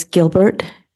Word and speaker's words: Gilbert: Alice Gilbert, A-L-I-C-E. G-I-L-B-E-R-T.